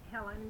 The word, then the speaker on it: Helen: Helen Basket Hester, H-E-L-E-N, B-A-S-K-E-T-T, H-E-S-T-E-R.